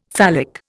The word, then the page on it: phallic: Indian